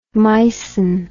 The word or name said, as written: mycin